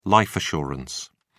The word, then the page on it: life assurance: British English